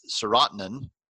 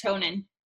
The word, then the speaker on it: serotonin: It also drops serotonin. I hope I'm saying that right. – I think it's serotonin. – Serotonin, you're right. Yeah.